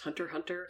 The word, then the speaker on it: Hunter x Hunter: You have a note here that says id equal Hisoka from Hunter x Hunter, or is it just Hunter Hunter? – It's Hunter Hunter. The X. – I've always said Hunter Hunter, yeah. – There is no pronunciation of the X, it's just there for the look.